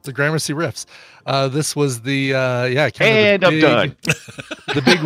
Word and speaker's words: Gramercy Riffs: I'm gonna go with the Warriors, but they always go against, and I can never say this right, so I'm gonna butcher it, but it's like the Gramercy Riffs or the Grandma Cry Riffs.